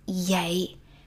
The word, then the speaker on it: vậy: Okay, we write vậy, we pronounce yay. So V like a Y sound, and also it's very weak, so it sounds like Saya, saya, saya.